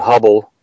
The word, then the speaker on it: Hubbell: and then include the name Hubble. It's spelled H-U-B-B-E-L-L versus Edwin Hubble's name.